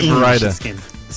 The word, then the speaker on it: Vareide: Then I'll start saying Vareide. – See, I took some German, and so I know that the EI is pronounced I.